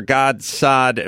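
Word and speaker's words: Gad Saad: Dr. Godsad. Am I saying that right? Did I screw that up? – Godsad. – Godsad.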